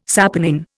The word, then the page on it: saponin: Indian